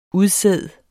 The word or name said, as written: udsæd